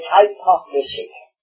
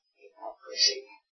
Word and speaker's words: hypocrisy: I think it was the late Frank Knox on one occasion said, some people pronounce it hypocrisy, But there's nothing high about hypocrisy.